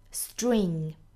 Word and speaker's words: string: 们要知道在 s 后面的清辅音呢要浊化发音比如说我们知道的讲话 speak 还有像这个运动 sport 都是 p 浊化成 b 的发音 speak sport 那么今天这个单词也是一样的虽然它的音标写的是 t r tr 但是我们要知道 s 后面的这个 tr 要浊化成 dr 的发音 string